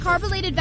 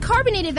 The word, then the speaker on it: carbolated: DL, I heard you mispronounced carbolated Vaseline, and you ended up saying carbonated Vaseline, and swore you were saying it right.